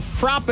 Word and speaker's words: frappe: You know how they pronounce it? Frap. Really? Frap. – Sounds like you just swore. – Nope. In Boston, where it started, it's a frap.